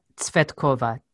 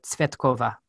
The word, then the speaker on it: Tsvetkova: Yeah, Yulia Tsvetkova. It's hard. It's three consonants together that don't usually happen in English, but Tsvetkova.